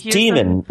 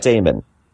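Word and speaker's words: daemon: So it's demon, not Damon.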